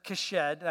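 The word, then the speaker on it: hesed: So this word, kesed, I know I'm saying with a K, and that's because that's how Hebrews do it. So it's H-E-S-E-D, but pronounced kesed.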